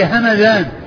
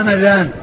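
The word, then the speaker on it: همذان: أما ما كان نسبة إلى بلدة فهي همدان, مدينة من المدن اسمها همدان بالميم المفتوحة والذال المعجمة.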